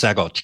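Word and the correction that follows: Saguache: County, right? – Swatch County, yeah. – Swatch County. I couldn't pronounce it. I've seen it written, but never heard it.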